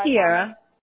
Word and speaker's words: Tiara: It's spelled the exact same way, and it's pronounced totally different. – So. Oh, really? Yes. – Yeah, a lot of people call so me Kiara.